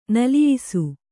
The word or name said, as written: ನಲಿಯಿಸು